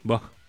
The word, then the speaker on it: Vogue: A Vogue, como se pronuncia. Sí, Vogue.